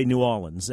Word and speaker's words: New Orleans: I say New Orleans. I pronounce that R. New Orleans. – I say New Orleans. N-O-O-A-W-L-I-N-S.